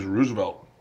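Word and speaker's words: RSVLT: Is Roosevelt. – Oh. – But although it's spelled without the vowel, so it's RSV.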